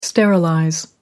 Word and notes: sterilizing: American English

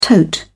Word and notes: tote: British English

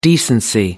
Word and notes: decency: American English

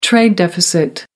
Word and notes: trade deficit: American English

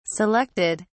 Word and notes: selected: British English

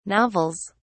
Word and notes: novels: British English

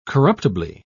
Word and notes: corruptibly: British English

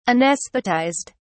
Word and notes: anaesthetized: British English

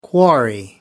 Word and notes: quarry: American English

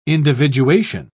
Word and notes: individuation: British English

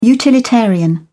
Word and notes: utilitarian: British English

